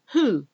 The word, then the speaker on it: who: Who? Who said this? W-H-O. Who? So it's a... The W is silent. You don't say who. It's who. Like just with the H. Whole. The whole. The whole thing is about silent letters. W-H-O-O-L-E. W-H-O-L-E.